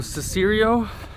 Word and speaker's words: Cicero: All right, so Cicero,